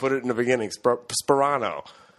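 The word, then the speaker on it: Sparano: It's Tony Sperano. Sperano. – Yeah, Sperano. Tony Sperano. – The P's in the beginning. – Oh, okay. – Yeah, it's not Soprano.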